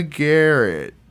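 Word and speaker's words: Garecht: The duo is suspected of murdering at least 15 people, including possibly Michaela Garrett.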